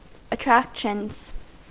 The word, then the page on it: attractions: American English